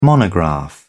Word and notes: monograph: British English

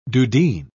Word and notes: dudeen: British English

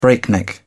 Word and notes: breakneck: British English

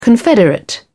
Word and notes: confederate: British English

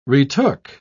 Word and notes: retook: British English

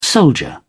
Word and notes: soldier: British English

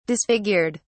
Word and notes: disfigured: British English

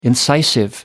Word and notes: incisive: American English

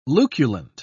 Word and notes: luculent: British English

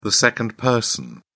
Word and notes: second person: British English

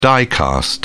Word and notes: die-cast: British English

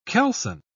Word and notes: keelson: British English

